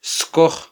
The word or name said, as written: skoj